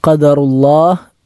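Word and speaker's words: ഖദറുല്ലാഹ് (قدر الله): ഖാഫ് എന്നത് അണ്ണാക്കിൽ നിന്നും പുറപ്പെടേണ്ട അക്ഷരമാണ്. ഖാ എന്നത് ഖദറുല്ലാഹ് എന്ന് പറഞ്ഞാൽ തെറ്റാകും. ഖാഫാണ് ഖദറുല്ലാഹ്. വമാ ശാഅ ഫഅൽ, അവിടെ ഫഅൽ എന്നതിൻ്റെ ഐൻ, ഫഅന് ശേഷമുള്ള ഐൻ തൊണ്ടയുടെ മധ്യഭാഗത്ത് നിന്നും പുറപ്പെടേണ്ട അക്ഷരമാണ് ഐൻ.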